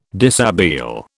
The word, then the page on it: dishabille: British English